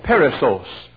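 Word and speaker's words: Perissos (περισσός): But there's a word he uses called perissos. P-E-R, we spell it P-E-R-I-S-S-O-S.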